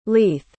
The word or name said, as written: leith